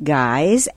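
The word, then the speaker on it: Gies: G-I-E-S. G-I-E-S. Pronounced Guys, and Micah Wong? – Yeah.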